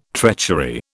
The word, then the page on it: treachery: British English